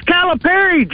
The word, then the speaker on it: Calipari: Hell, Keith, it's 2026 and you're still referring to Bert as Belima. – Hell, Chief. – Johnny Tyson is paying our basketball coach $8 million and you can't be bothered to get his name right? It's Calipari, Jethro.